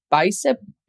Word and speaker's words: Bicep: Could you educate us on what is a Bicep template? And am I saying it correctly? – Yeah, you are.